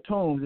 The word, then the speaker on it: Toombs: You know, my name is Larry Toombs. It's, you know how to spell it. It's T-O-O-M-B-S.